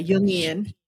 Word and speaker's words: Jungian: it's pronounced Jung, like a Jung. Y, Jungian. – Uh-huh. Jungian.